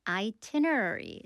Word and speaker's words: itinerary: And then the chatbot, or rather the AI agent, can figure out your itinerary. 스펠링도 보겠습니다. I-T-I-N-E-R-A-R-Y. Itinerary. 그 첫 번째 I는 그대로 발음해 주세요.